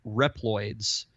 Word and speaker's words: Reploids: And these robots were called Reploids.